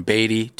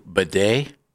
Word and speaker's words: Badie: Why a guy would change the pronunciation of his last name from Beatty to Bidet, I have no idea, but he did. Or is it Tyson? Tyler or Tyson? He's from Missouri. His name was Beatty, B-A-D-I-E. And then he changed the pronunciation to Bidet.